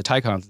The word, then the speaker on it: Taycan: Porsche makes an electric car called the Taycan, T-A-Y-C-A-N, but they pronounce it Taycan.